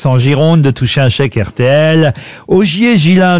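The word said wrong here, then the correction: Ghiselin: de Busbeck, pardon, j'ai écorché son nom. – Il me semblait bien aussi. – Ça, c'est ça, oui. Est-ce que vous pouvez appeler Busbeck? – Busbeck. Augier Gislain de Busbeck. – Ah, Gislain.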